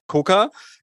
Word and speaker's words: Quooker: Es ist doch einfacher, als man denkt. Es ist dann doch einfach nur der Koka.